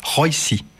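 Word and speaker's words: Róisín: Alors, elle est irlandaise, il faut prononcer Roisin.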